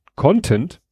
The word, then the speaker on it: content: Äh, kleine Aussprachehilfe beim Con Content im Sinne von Inhalt wird die erste Silbe betont. Betont man die zweite Silbe, klingt es nach Content im Sinne von zufrieden.